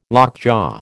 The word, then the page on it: lockjaw: American English